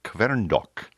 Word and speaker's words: Kverndokk: His name is Gisle Kverndokk. The last name is spelled K-V-E-R-N. D-O-K-K.